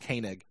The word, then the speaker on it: Koenig: Yeah, so apparently there was now the name of the actor there, his name is Walter Koenig or Koenig, I think it's actually okay. Pronounced Koenig.